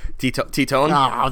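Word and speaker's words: Tytoń: I'm not even sure that's how you say it, but I love that. – It's not, but we'll let it go. – How do you say it? – Titone? – Oh, that's no fun.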